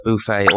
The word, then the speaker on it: buffet: Hotel buffet, B-U-F-F-E-T. I know the spelling, but the pronunciation, please correct me. – Well, this word actually can have both pronunciations. So you could say a buffet or you could say a buffet.